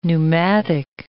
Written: neumatic

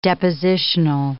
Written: depositional

